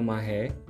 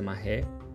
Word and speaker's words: Marais: Tem um bairro gay, que é o Marré, e que escreve Marais, mas pronuncia Marré.